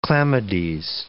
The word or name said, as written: chlamydes